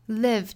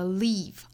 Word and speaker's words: live: Live 这个词不要发成了 leave、啊、应该是 live，松口的 it 的发音。